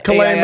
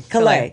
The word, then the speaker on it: Calais: Calais, Maine. Calais, Maine. – Calais.